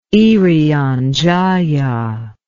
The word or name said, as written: irian jaya